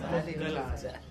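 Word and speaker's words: Moggallāna: Moggallana. – Moggallana. Moggallana, right? Moggallana. Long A.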